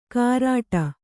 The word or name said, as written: ಕಾರಾಟ